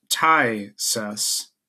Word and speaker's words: phthisis: It says an overconsonanted Greek word meaning a dwindling or wasting away, pronounced thysis.